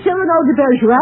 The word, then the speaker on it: Cyrano de Bergerac: Sereno, Bergerac. How about that? – You mean that the name right? – What was that? – You got the name right. You mean Cyrano de Bergerac? – Yes.